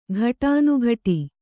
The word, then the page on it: ಘಟಾನುಘಟಿ: ghaṭānughaṭi